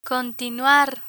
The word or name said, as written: continuar